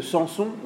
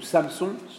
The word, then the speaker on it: Samson: le récit de Samson, ou Samson, selon la prononciation, ou Shimshon en hébreu,